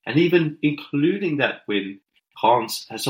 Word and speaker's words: Reims: Yeah, but yeah, Reims.